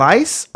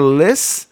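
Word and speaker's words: Lys: Lice or Liss? – Liss. – It's L-Y-S, Brian.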